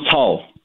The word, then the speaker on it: Cao: Is it Pastor Cao? – Yeah, Pastor John Cao.